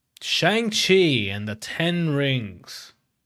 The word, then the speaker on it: Shang-Chi: I keep saying Shang. What the hell's my problem? Shang Chi. That's better, right? Why you laughing? That's not. I said it right that time.